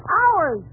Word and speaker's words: hours: Now, how do you pronounce H-O-R-S-E? – H-O-R-S-E? Oh, that's easy. That's horse. – Fine. Now, how do you pronounce H-O-U-R-S? – H-O-U-R-S? Howard? – You pronounce it ours.